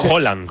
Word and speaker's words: Haaland: Es Haaland. – Haaland. Como si dijeras un queso, ¿no? – ¿Sabes qué pasa el martes?